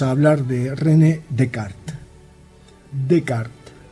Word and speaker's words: Descartes: En francés, las letras S que están al final no se pronuncian. Y habitualmente tampoco se pronuncian las E que estén al final, salvo que estén acentuadas, como René. El tipo se escribe Descartes, pero la S la pasamos por alto, la dejamos para otro día, salvo que estuviera con una vocal en lo posible fuerte. Así que aunque se escribe Descartes, se dice Descartes.